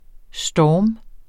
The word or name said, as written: storm